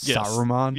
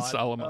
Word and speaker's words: Solomon: When I kept pronouncing it like it was Saruman. – Yes. Oh, God. Solomon.